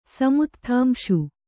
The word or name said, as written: ಸಮುತ್ಥಾಂಶು